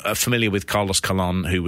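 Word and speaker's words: Colón: Wikipedia, it is spelled colon. yeah. yeah. but there has got like the uh, the um, apostrophe over the top of the oar to indicate.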